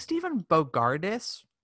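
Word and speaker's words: Stephen Bogardus: I think Stephen Bogardis won. Is that how you say it? – Yeah. Oh, yes.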